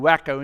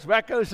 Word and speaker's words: Waco: Did I say it wrong? I think they are wacko. That's how we said it in South Africa, those wackos in wacko Texas.